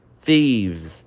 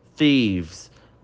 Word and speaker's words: thieves: Essa palavra tem uma pronúncia um pouco difícil, tá? Repete aí, olha. Thieves. O TH ele quase que faz um som bem leve deslizando pro I. Thieves.